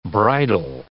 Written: bridal

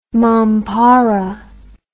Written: mompara

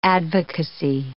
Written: advocacy

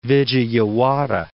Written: Vijayawada

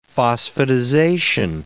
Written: phosphatization

